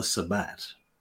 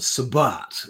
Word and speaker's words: Sabbat: Anyway, then there is the Sabbat. Or the Sabbat, or however you want to pronounce that, S-A-B-B-A-T, mm-hmm. not to be confused with Black Sabbath, or the Sabbath.